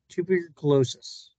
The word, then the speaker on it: tuberculosis: In 1901, Dean's mother died from I always have trouble pronouncing this, Brandon. Tuberculosis. – That's tuberculosis.